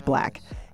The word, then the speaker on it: 6LACK: He spells his name 6LACK, and that's causing many people to wonder, how do you say it? Well, it's Black.